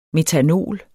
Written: metanol

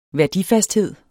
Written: værdifasthed

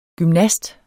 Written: gymnast

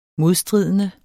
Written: modstridende

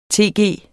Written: tg

